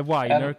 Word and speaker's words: Weiner: När han sa Matthew Weiner, så bara, eller Weiner kanske man uttalar det, så bara kände jag, nej fan, jag känner igen.